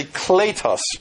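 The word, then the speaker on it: Kletos (κλητός): and that's wrong because the long sound is actually like our long A in the Greek, so it's really Kletos Apostolos.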